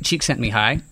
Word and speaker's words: Csikszentmihalyi: but if you are spelling it at home, his name is Mihai Csikszentmihalyi. It is, the last name is C-S-I-K-S-Z-E-N-T-M-I-H-A-L-Y-I.